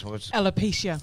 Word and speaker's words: alopecia: Alopecia.